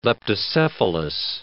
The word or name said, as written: leptocephalus